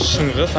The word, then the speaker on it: Shyngys: Oh, so my name is Shunguz.